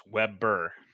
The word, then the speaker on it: Webber: I have two syllables. Webber.